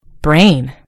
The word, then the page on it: brain: American English